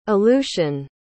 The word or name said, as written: aleutian